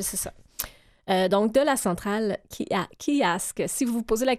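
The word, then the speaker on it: Keeyask: Kiask.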